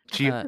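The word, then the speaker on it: Tchia: Tichia? Is that how you say it? – Chia. – I said it Chia, yeah.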